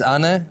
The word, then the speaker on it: Anne: And this is Anna, but it's written Anne.